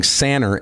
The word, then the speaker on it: Sanner: But it's actually pronounced Levan, and I can relate to that, because my last name, of course, being Sanner, S-A-N-N-E-R, everybody wanted to call me Sanders.